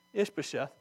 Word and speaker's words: Ishbosheth: Oh, I said it right, I didn't even look at it. Ishbosheth.